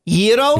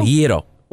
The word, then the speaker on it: gyro: I asked the Greek guy. – Yeah, what'd he say? – He says it's gyro body. – Yero? – Yero. – Y-E-R-O is how you pronounce it?